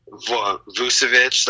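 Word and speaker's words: Vučević: I know Nikolai Vucevic, that's how you pronounce his name, came out strong with a 40-plus game.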